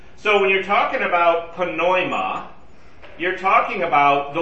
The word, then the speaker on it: pneuma: We would drop the, we would make the P silent in our, in English. Pneuma, as in pneumatic or pneumonia. P-N-E-U-M-A. Okay? The Greeks pronounce it. They don't have any silent letters. So they would actually pronounce it pneuma.